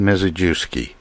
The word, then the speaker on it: Mizejewski: And I'm glad to introduce David Mizejewski.